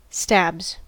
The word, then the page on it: stabs: American English